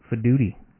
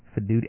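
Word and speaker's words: Faidutti: Fiduti. – Fiduti? – Fiduti. I might be saying his name wrong, but it sounds like duty.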